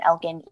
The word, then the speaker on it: Elkin: And Lily is spelled LILLIE and then Elkin, ELKIN.